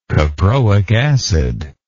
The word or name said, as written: caproic acid